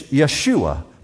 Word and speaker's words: Yeshua: In Hebrew, it's Yeshua, that's the way they would have pronounced it.